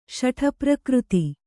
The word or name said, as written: ಶಠಪ್ರಕೃತಿ